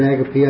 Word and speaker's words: agape: I've always pronounced it agape. I think that's correct.